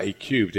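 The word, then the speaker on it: A³: A-Cubed? – Yeah, A-cubed. Well done, yeah.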